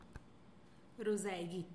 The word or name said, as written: ruzeegitx